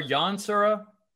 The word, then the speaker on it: Yansura: Yansura or Yansura? – Gosh, I've been told Yansura, like with the kind of sounds like with the E, but spelled with an A.